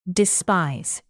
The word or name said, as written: despise